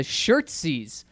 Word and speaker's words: shirtsies: He says, hey, guys, coming in to stick up for the shirtsies. What are we calling these things? – They're called shirtsies. Shirtsies. – It rhymes with jerseys.